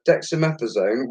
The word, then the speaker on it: dexamethasone: There's a new drug called, and I'm going to put this out to however I say this wrong, it's called dexamethasone. Is that right, Jay? – You're right there, mate.